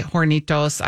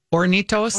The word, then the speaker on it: Hornitos: Someone else was chastising me for, and maybe rightly so, that when we say hornitos, I pronounce the H, that it's just supposed to be hornitos.